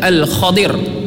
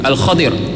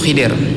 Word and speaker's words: Al-Khadir: Yang kalau dalam riwayat hadis yang sahih, beberapa hadis yang sahih, di antaranya diriwayatkan oleh Ubay bin Ka'ab radhiyallahu an, ejaan dia adalah Al-Khadir. Kha-di-ru. Al-Khadir. Tapi orang kita biasa sebut Khidir.